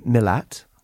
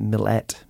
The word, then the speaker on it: Milat: But Bill Ivan, his brother, said everyone has been saying the name wrong. Oh. And it is pronounced Millet.